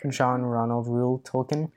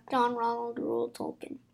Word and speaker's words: John Ronald Reuel Tolkien: John Ronald Reuel Tolkien. – John Ronald Reuel. Try to say that. – John Ronald Reuel Tolkien.